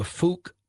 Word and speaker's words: Fouke: So if you're listening, this sounds a lot like the Fouque monster. I believe that's how it's pronounced. I have to be very careful. F-O-U-K-E.